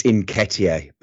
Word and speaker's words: Nketiah: It's Nketiah, by the way.